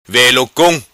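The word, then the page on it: Velo Cong: patua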